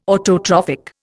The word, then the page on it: autotrophic: Indian